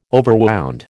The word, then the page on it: overwound: American English